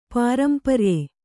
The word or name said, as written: ಪಾರಂಪರ್ಯ